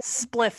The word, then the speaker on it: spliff: I would like to start off this year with a little spiff slips because we record... – Spliff slips. – What did I just say? – Spiff. – I said it really slowly because I knew I was going to say it wrong and I still said it wrong.